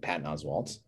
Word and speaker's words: Patton Oswalt: one of them being Patton Oswalt. So, I mean, it's further politicization. No, that's not how you say it. – That is it. – It is? – It is.